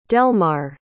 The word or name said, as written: delmar